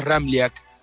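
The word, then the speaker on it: Ramljak: Es un poco complicado porque tiene tres consonantes juntas y la J se pronuncia como una I. Bien pronunciado el apellido es Ramliak.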